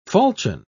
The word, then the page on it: falchion: British English